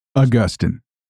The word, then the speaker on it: Augustine: So Augustine or Augustine, can I make a confession? No pun intended. I honestly can never remember which way I'm supposed to pronounce it. Which way is it anyway? – Augustine. – That's what I thought.